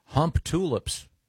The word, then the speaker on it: Humptulips: In Grays Harbor County, Washington, there is a town called Hump Tulips. – Hmm. – I wonder if they pronounce, maybe it's pronounced Humptalips. – Boy, Humptalips is almost more offensive. – Yeah. – It's Humptulips. Yeah, Humptalips sounds like a purple giraffe on a kid's TV show.